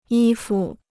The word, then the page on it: 衣服: yī fu